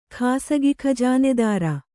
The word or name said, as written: ಖಾಸಗಿಖಜಾನೆದಾರ